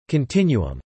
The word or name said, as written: continuum